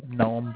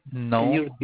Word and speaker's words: Gnome: It's the Gnome Book Writing. How are you? – From which company? – Gnome Book Writing.